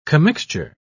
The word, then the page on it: commixture: British English